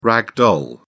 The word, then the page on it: rag doll: British English